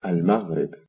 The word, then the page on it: Morocco: Arabic